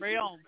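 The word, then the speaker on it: Reaume: The reinstatement of Mike Wallace and Josh Rehm? – Real. – Is that how you say it? – Real.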